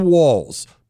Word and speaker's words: Walz: I keep calling him Tim Walz and Walz, and I just have to remember it rhymes with balls. So Tim Walz, balls, Walz.